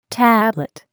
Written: tablet

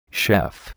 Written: chef

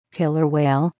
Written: killer whale

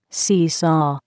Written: seesaw

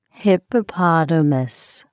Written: hippopotamus

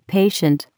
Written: patient

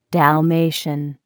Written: Dalmatian

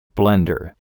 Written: blender

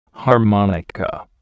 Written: harmonica

